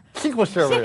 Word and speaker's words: SQL Server: SQL Server is actually how it's pronounced in the industry.